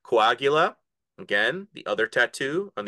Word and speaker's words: coagula: Is that correct? How am I pronouncing it? – Yep, coagula. Okay.